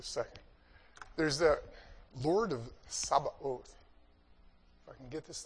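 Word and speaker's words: Sabaoth (צבאות): In English, we pronounce what they say, va, as ba. We put b's instead of v's. They're very close in many languages. And then the ot. So it's Adonai, the Lord, Sabaoth.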